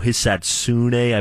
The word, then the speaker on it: Hisatsune: So Hisatsune, I believe, is how you say it.